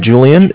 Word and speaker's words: Julian: My name is Julian. It's J-U-L-I-A-N.